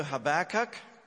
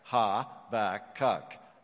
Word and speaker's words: Habakkuk: And as I said, we're going to Habakkuk. I think you got to say it with me. Let's get it. Habakkuk. Let's do it again. Habakkuk. Ha-ba-kuk. Habakkuk.